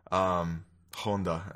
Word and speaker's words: Honda: Here's our review for Godzilla 1954, directed by Ishiro Honda. Honda, I should say. I know Japanese, I should say it properly.